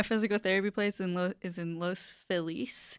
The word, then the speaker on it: Los Feliz: At least say the name correctly. – Say the name correctly. – I think I say it that way. – It's Los Feliz, bro. – It is Los Feliz.